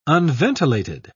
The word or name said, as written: unventilated